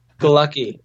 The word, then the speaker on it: Galucki: Galecki is Polish, and it's weird. It's spelled G-A-L-U-C-K-I. It's like Happy Go Lucky, except with an A instead of an O. So if that helps you remember it. – Andrew, G-A-L-U-C-K-I.